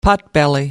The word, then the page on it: potbelly: American English